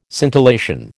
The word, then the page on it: scintillation: American English